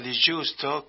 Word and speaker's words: Di Giusto: El otro día hablamos con Gerardo Di Giusto, que también es un, yo lo pronuncio en italiano porque, bueno, es italiano eso, pero él es otro cordobés también que se fue a fines de los 80 y no está viviendo en París.